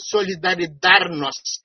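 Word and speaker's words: Solidarność: eu queria dizer Solidaridarnosc, que é como se fala em polonês,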